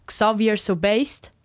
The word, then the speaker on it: Xavier Sobased: Fun Fact, wie du vielleicht noch gemerkt hast vorne, Close Friend von Xuvi, nämlich Xavier Sobased, spricht man so aus, ja? – Ja.